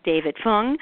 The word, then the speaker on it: David Fung: concert pianist David Fung.